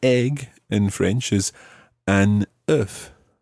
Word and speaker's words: œuf: So if you are looking for an egg, it's an œuf, and you pronounce the F at the end.